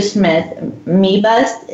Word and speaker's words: Smith-Mebust: Andrew Smith-Mebust, is that how you say your last name, Andrew? – Yeah, you nailed it.